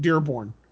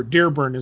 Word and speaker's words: Dearborn: Up at the Greystone Theater in Dearborn, or Dearborn as the locals say.